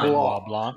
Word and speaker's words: Benoit Blanc: how do you pronounce Daniel Craig's character's name? Benoit, Benoit Blanc. – Blanc.